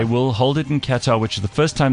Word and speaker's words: Qatar: It's pronounced Qatar.